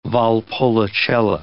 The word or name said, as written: Valpolicella